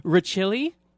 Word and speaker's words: Rachilli: Like five times I have heard it pronounced Rosilli. I know. Every time I see in public it, they always say it. – And I was informed by Marcus himself today that it's Rachilli.